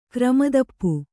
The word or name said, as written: ಕ್ರಮತಪ್ಪು